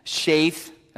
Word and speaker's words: Sheth: We learn that Adam was 130 years old when Sheth, that's the Hebrew pronunciation of it,